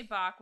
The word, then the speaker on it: Bach: Is it B-A-C-H, like the composer? – Yeah, B-A-C-H. – That's Bach. – Okay. – That's Bach. – Renee Bach. – So dun-dun-dun-dun. – In 2007, Renee Bach was a teenage volunteer at a missionary-run orphanage in a rural village of Lake Victoria Jinja, which is...